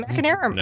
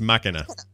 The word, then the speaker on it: Machina: Is it Machinera? No. I can't pronounce it. – It's Machina. – It's Machina.